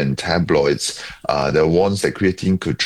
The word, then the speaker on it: creatine: Creatine. Is that how it's pronounced? – Yeah, creatine. Yeah.